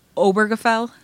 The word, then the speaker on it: Obergefell: Obergefell versus Hodge is... So it's Obergefell? – It's Obergefell. – Obergefell versus Hodges. – Yep. – Oh my gosh. Obergefell. – No, you said it right.